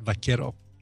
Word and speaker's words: vaquero: Or vaquero. I'm sorry, that's probably it. Vaquero. You don't pronounce it qua.